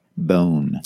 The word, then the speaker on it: Boon: It's spelled B-O-O-N, which sounds like Boone, but I think the pronunciation is more like bone.